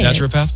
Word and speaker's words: naturopath: Are we saying it right? – I believe that's how you say it. – Naturopath? – Yeah.